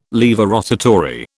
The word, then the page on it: laevorotatory: British English